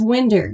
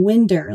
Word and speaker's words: Winder: It's Winder. You got it right. – Yes! – Yeah. I, like, in my mind, it's always been Winder.